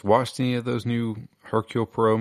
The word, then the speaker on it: Hercule Poirot: Agatha Christie, is it Hercule Poirot? – The London Hercule Poirot. – Hercule Poirot, there you go.